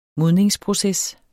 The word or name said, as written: modningsproces